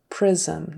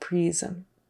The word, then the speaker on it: prism: it should be an I, which is what we would call a short I sound. So I instead of E. So prism and division, division, not division. Division. Prism, not prism.